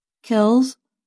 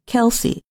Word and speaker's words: Kelce: Whether Kelce should be pronounced with one or two syllables, Kells or Kelsey, has been a subject even his own family talks about. Both Travis and his brother, Philadelphia Eagles center Jason Kelce, say their last name is Kelsey.